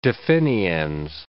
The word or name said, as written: definiens